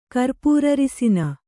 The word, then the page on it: ಕರ್ಪೂರಅರಿಸಿನ: kannada